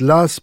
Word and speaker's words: Law: on prononce l'As parce qu'il écrivait mal le W de son nom, on lisait deux S, la spéculation atteint des proportions jamais vues.